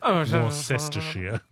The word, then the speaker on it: Worcestershire: Let me give you some help, AJ. It's Worcestershire sauce. Worcestershire.